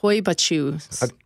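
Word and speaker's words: Hoia Baciu: Hoibachu. – How do you spell that? – H-O-I-A. – Yeah. – And then B-A-C-I-U.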